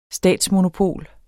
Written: statsmonopol

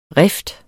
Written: rift

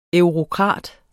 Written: eurokrat

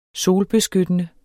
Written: GYLDNET

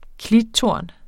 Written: klittorn

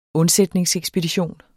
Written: undsætningsekspedition

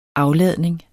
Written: afladning

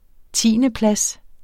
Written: tiendeplads

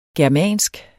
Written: germansk

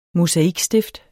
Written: mosaikstift

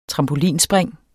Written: trampolinspring

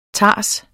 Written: tars

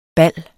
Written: bal

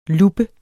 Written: LUBBEN

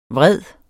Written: VREDESTE

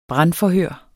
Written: brandforhør

